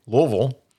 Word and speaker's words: Louisville: Louisville, in 2002, and it started with 150 fans showing up and has since expanded to several other cities.